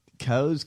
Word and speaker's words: Kos: Daily Cause? K-O-S. – Oh, K-O-S. Oh, okay. – How do you say it? – I say Cause. It might be Cause.